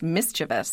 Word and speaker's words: mischievous: But I remember sitting at my desk at Merriam-Webster and looking at this word and realizing, oh, there is no I-O-U-S. It's mischievous.